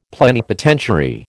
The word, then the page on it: plenipotentiary: American English